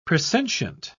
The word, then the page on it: presentient: British English